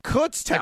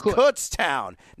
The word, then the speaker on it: Kutztown: It is Kutztown, correct? – No. – Kutztown. Like foot. Kutztown. Not yeah. Cuts. Not Coots. Kutztown.